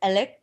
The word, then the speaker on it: Alex: And his name, even though it's spelled Alex, it's pronounced Alec.